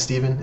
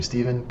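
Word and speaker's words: Stephan: My name's Steven. It's spelled with a PH and an A, so you'd think it's Stefan, but it's actually Steven, because my dad's kind of weird like that.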